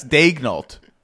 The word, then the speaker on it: Daigneault: Coach, say Daigneault. It's Daigneault.